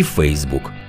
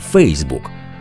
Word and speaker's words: Фейсбук: Українські мовознавці спершу схилялися до того, що наголошувати потрібно на першому складі Фейсбук. Мовляв, так кажуть англійці, і нема підстав застосовувати якусь іншу форму. Проте нині підтримують наголос на другому складі Facebook. Можливо, через те, що такий варіант більше прижився.